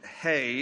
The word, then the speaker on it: He: Now, it's the Hebrew letter is just spelled H-E. So in English, we would look at that and say it's He, but it's pronounced Hey.